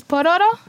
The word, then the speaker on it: Pororo: It's pororo. It's R, like an R sound. – You gotta, right? – You gotta roll the R.